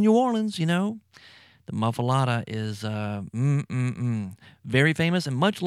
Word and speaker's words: Muffuletta: It's called the Muffuletta. The Muffuletta, and it's spelled M-U-F-F-U-L-E-T-T-A, Muffuletta. And sometimes you'll find somebody calling it a Muffuletta, but most people call it a Muffuletta.